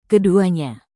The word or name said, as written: keduanya